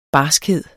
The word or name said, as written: barskhed